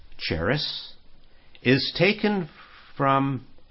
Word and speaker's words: charis: The Greek word for grace in the New Testament, a little word study, it's number 5485, and it's CHARIS, pronounced charis, and it comes from 5463.